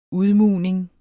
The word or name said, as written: udmugning